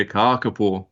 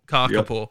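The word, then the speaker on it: kākāpō: Karkapur. Yep. Um, got a long A on them. If you if you have a short A, Kaka means poo, but uh, kaka means the parrot, so kakapone.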